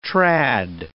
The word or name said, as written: trad